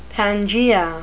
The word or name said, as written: pangaea